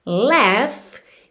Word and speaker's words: laugh: Laugh se escreve L-A-U-G-H, mas a pronúncia é laugh, e esse G-H você vai fazer pronúncia de F mesmo, ok? Laugh.